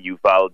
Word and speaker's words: Yuval: Yuval, I hope I got that. Is it Yuval, Mr. Yes. – David? Is it Yuval? – Yeah. Look, I think I've gotten so used to many different pronunciations of my name over the years, but it's absolutely Yuval David with a name like this. It's pronounced in different ways.